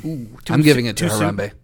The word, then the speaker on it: Harambe: Karambe. – Wait, is that how you say his name? I don't even know. – It's Harambe.